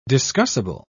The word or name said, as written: discussible